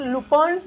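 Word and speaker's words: Lupin: Que por cierto, por ahí mi querida Vane, se escribe Lupín, pero es Lupón, ¿eh?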